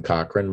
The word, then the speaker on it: Cochran: And so you can find me, Jason Cochran. My last name is spelled C-O-C-H-R-A-N.